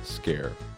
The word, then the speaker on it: Sker: I'm your host, and today I've got a whole bunch of games to talk about, including Maid of Sker, spelled S-K-E-R. So I really want to say Maid of Sker, Mighty Scar, but it's Maid of Sker, I guess. I forget what it is, Scandinavian or something, but apparently it's pronounced Scare, which I appreciate for the not being Scare, but sounding like Scare aspect of it.